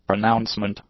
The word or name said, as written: pronouncement